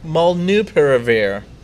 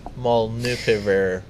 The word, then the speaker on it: molnupiravir: Natty, you've got some more information on molnupiravir. No, molnupiravir. – Is it molnupiravir? That's how I've been reading it all molnupiravir. – This time. Yes.